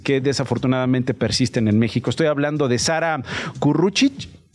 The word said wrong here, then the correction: Curruchich: Eh, Nacho, es curruchich. Curruchich. Ah, bueno, curruchich. – Bueno, es que es una palabra en cachiquel que significa pájaro carpintero.